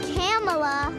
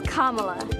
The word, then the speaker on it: Kamala: Just combine comma with la, and you get Kamala.